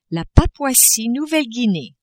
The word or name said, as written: Papouasie-Nouvelle-Guinee